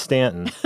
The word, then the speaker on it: Staunton: It's not pronounced the way it's spelled. Just so listeners know, it's spelled S-T-A-U-N-T-O-N, but we don't pronounce the U. I don't know why. It's Stanton, Virginia, and you went down there and you produced with our colleague Mark Williams Holzer this amazing photo essay.